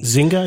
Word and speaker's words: Zynga: Zynga, I think? – Zynga, yeah.